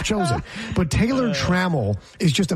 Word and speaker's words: Taylor Trammell: There's two syllables, Taylor Trammell, and then it goes down and up.